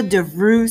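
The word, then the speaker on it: Devrou: So Pamela DeVrue Smith. Just in case you don't know how to spell that, that's D-E-V as in Victor, R-O-U, DeVrue. That's my maiden name, Pamela DeVrue Smith.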